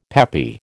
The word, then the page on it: peppy: American English